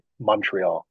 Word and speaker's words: Montreal: Okay, so the pr- pronunciation, if you want to be more Canadian, people from Canada never say Montreal; they say Montreal, almost like it's a U, like Montreal.